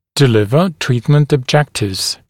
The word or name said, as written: deliver treatment objectives